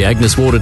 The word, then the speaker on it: Agnes Water: heading down to Agnes Waters? – Mate, it's pronounced Agnes Water.